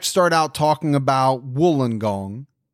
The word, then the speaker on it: Wollongong: You would think. – But I've been told that it's Wollongong, so I'm going with that.